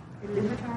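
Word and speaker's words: Ilúvatar: It starts off with Iluvatar, am I saying that right? – Yeah.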